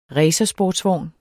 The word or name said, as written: RACERSPORTSVOGN